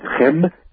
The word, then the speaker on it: Kemet: called Kemet or Kempt. Actually, it's Chem, if you pronounce it correctly, and the Arabic term, one of the Arabic terms for Egypt, Al-Chem, is where the word alchemy comes from.